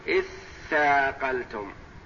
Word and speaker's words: اثاقلتم: اثاقلتم إلى الأرض، اثاقلتم أصلها تثاقلتم، تثاقلتم تاء وثاء، فأدغمت التاء في الثاء لتقاربهما،